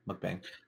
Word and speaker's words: mukbang: You might be a mukbang star. Is that how you pronounce it? – Yeah, mukbang.